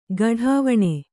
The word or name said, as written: ಘಡಾವಣೆ